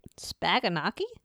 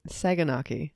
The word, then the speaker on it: Saganaki: Spaganaki? – Saganaki.